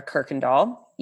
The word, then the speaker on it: Kirkendall: My name is Alexandra Kirkendall. You would never know that that's how you pronounce it based on how it's spelled, but we try to go with the Dutch as closely as we can.